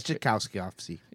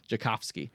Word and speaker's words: Tchaikovsky: Yeah, it's Tchaikovsky. – Yeah, Tchaikovsky.